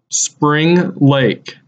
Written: Spring Lake

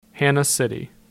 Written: Hanna City

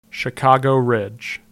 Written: Chicago Ridge

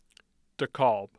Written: dekalb

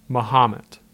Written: Mahomet

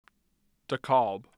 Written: DeKalb

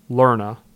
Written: Lerna

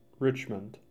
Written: Richmond

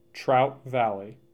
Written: Trout Valley

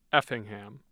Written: effingham

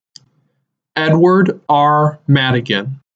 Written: Edward R. Madigan

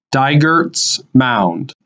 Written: Dygerts Mound

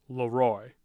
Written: LeRoy

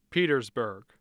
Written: petersburg

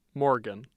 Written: Morgan

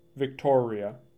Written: Victoria

